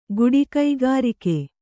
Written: ಕಾಡಿಗ್ಗರಗೆ

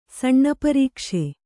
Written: ಸಣ್ಣಪರೀಕ್ಷೆ

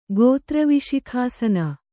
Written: ಗೋತ್ರವಿಶಿಖಾಸನ

